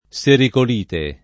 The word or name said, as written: sericolite